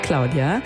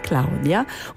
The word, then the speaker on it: Claudia: Bonsoir, ciao ragazzi, ich bin's Claudia, schön italienisch ausgesprochen, Claudia.